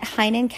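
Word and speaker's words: Heynen: Last name is spelled H-E-Y-N-E-N. I am on Instagram at Heinen Counseling and Coaching.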